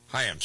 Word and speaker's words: Chaim: It's pronounced Chaim, though, I think. – Chaim, sorry.